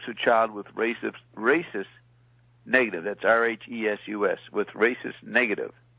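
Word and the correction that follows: Rhesus: No. Carolyn's pronouncing it for you.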